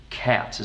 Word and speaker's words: qat: And there are so many times, I marked every single time, I think, in the book, when you mentioned a drug called Cat, is that how you pronounce it? Mm-hmm. Q-A-T? – Yep.